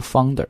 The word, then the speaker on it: founder: 创始人不读 founder，应该读 founder，因为在这里实际是一个双元音 ,ow, found, founder, OK，但是你千万不要读成 founder,、啊、 founder 不对 ,founder, ow 这个音在这里，所以呢，大家如果想去纠音的话啊，我们的暴虐晨读实际都会讲得很细。